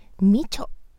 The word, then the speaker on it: 美酢: 本日紹介したいのは、美しい巣と書いて、みちょと読む商品です。